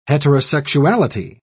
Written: heterosexuality

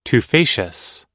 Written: tufaceous

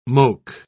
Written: moke

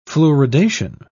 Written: fluoridation

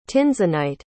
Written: tinzenite

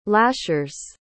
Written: lashers

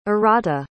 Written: Arata

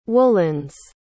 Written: woolens

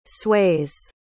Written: sways

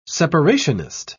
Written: separationist

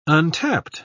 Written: untapped

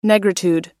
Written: negritude

